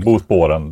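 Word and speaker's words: Bosporen: Och då måste de gå genom det här smala sundet mellan Asien och Europa, Bosporren. – Just det. Bosporren säger jag, men det är... – Ja, ja, Bosporren, det är nog rätt, mer rätt uttal.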